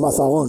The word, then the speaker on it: Mazagón: Va, pues Mazagón, lo he dicho bien. Entonces. – Mazagón, sí, lo ha dicho usted bien.